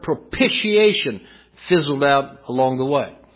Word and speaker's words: propitiation: And by the way, that is the correct pronunciation. Propitiation. It's as if there's a P-I-S-H in the middle of it.